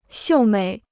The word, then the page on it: 秀美: xiù měi